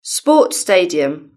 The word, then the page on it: sports stadium: British English